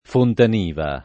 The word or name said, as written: Fontaniva